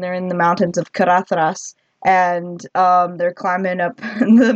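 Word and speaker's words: Caradhras: Carathras. That's how you say it.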